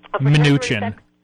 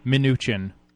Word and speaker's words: Mnuchin: how do you say his name, guys? Mnuchin? – Mnuchin. – I'm sorry, say it again? – Mnuchin. – Mnuchin, okay, like Mnuchin.